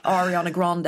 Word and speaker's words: Ariana Grande: Ariana Grande.